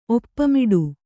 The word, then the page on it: ಒಪ್ಪ ಮಾಡು: oppa māḍu